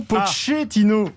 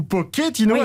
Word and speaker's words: Pochettino: Même sur la prononciation de son nom, Pochettino ou Pochettino à l'espagnol ou à l'italienne, eh bien, c'est comme vous voulez.